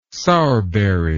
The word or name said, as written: sourberry